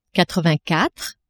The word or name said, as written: quatre-vingt-quatre